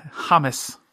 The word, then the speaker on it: James: Um, yep, I actually thought his name was James till you just said that, but yeah. Oh, really? Yeah, Hamas. That's how you say it.